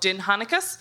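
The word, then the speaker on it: Dindshenchas: Shall we say, if you are so inclined, the kind of the lore of place names in medieval Ireland is called Dinhanicus. It's spelled not like it's pronounced. It's D-I-N-D-S-H-E-N-C-H-A-S.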